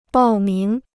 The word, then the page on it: 报名: bào míng